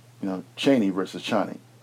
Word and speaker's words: Chani: And also too, cause Dwight and I did, again, we had conversations prior to this in this particular, incarnation of Dune, Zendaya's character's name is pronounced Chani. – Mm-hmm. – Right. Mm-hmm. But you said a Chaney, or people were unsure as to exactly what the pronunciation was supposed to be. – Yeah, the British audio representation I heard of it, they call it Chaney.